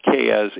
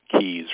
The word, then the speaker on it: Keyes: Even my last name, people have a tendency to say Keyes. No, it's not Keyes, it's Keys. Real simple, two syllables, Ted Keys.